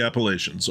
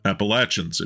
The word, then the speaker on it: Appalachians: It's a beautiful area, another one of the valleys in central PA, a gift to the Appalachians, or Appalachians if you prefer. The latter's a more southern pronunciation, but either is considered correct.